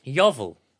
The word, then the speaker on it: Yeovil: My dad always said it was called Yeovil. – Yeovil? – He said that's how locals sort of pronounce it more than...